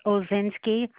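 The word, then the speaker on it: Odzinski: Yes, my name is Judith Ozinski. It's spelled O, D as in dog, Z as in zebra, I-N-S-K-I.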